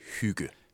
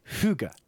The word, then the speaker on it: hygge: This concept of coziness, how do you pronounce it? – Hygge. – Hygge. – Hygge. And the pronunciation is rather important, because part of doing hygge is also invoking the term.